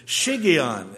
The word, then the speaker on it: shiggaion: shigion, and it's spelled S-H-I-G-G-A-I-O-N.